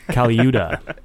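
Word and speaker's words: Colaiuta: Caliuda.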